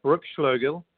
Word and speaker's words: Bruckschloegl: So Andy, I'm going to pronounce this correctly, Brooke Schlögel.